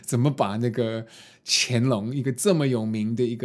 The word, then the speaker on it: 乾隆: 然后后来好像是我的老板说：“ 呃，大文那个字要要念干乾隆。” 那个不是干龙，那那个时候我觉得我好倒霉，因为好像旁边的观光客也觉得很呃，觉得就是谁会把这个字念成肝？